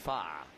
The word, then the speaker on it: Pfaadt: Pronounced Pfaadt as in the law one? – Yeah. I think it's more like fa. Fa. – Ah, fa. Okay.